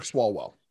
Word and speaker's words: Swalwell: The problem is with the misinformation is you have the Eric, say his name for me, Swalwell. – Eric Swalwell.